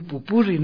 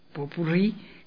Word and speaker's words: popurrí: Entonces, Ahora que dije esto de cuidado con decir pupurri, ¿no? Si no, es popurrí, que se escribe poutpurri.